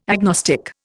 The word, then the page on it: agnostic: Indian